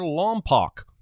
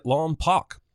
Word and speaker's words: Lompoc: Watson says he's from Santa Maria near Lompoc, pronouncing it Lompoc. If he actually lived there, he'd know it's pronounced Lompoc.